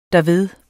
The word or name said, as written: derved